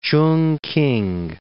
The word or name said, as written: Chungking